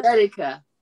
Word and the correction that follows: Athleta: No. – No. Athleta.